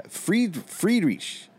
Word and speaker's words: Friedrich: The German way is the silent H, the CH is like a silent. – Friedrich, yeah. Friedrich is what they say.